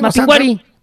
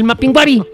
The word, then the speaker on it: Mapinguari: Ah, es que no tiene acento en la I. – Mapinguari. Dice el Mapinguari.